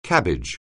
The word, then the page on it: cabbage: anglais